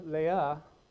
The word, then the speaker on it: Leah: The name of the older, Leah. Yes, that's how you actually pronounce that in Hebrew.